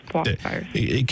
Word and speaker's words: Powassan: Is it Powassan? – Yes, Powassan.